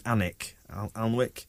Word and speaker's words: Alnwick: Right, speaking of keepers as well, we've brought in, I presume you pronounce this like the place, Ben Alnwick from Spurs,